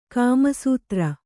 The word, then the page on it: ಕಾಮಾಸ್ತ್ರ: kāmāstra